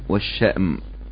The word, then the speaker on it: الشأم: ممكن تنطق الشام والشأم.